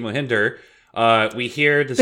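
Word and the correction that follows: Mjölnir: You called it Mohinder. – Yeah. – It's Mjolnir. – Mjolnir.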